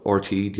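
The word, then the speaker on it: RTÉ: But RTE, the Irish television company, is that the right way to say it?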